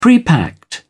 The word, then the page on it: prepacked: British English